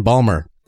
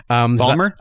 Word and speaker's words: Balmer: I'm 99% sure he's actually saying Balmer, which as Serge and I know is how you say Baltimore if you're from Baltimore. – Balmer? – Out of Balmer.